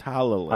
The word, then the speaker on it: Talalay: And Pearl Mackey as Bill and Mark Gatiss as the Captain, and is directed by Rachel Talalay. – Yeah, that's how Mike taught us to pronounce it. – That's how Mike taught us to pronounce Talalay.